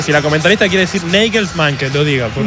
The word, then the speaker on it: Nagelsmann: Es Nagelsmann. – Claro, es Nagelsmann.